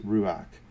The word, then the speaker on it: ruach: There's an interesting play on words in these verses in Hebrew, in the Hebrew word for breath, wind, and spirit are all the same word, pronounced ruach. And the context determines the interpretation.